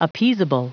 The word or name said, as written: appeasable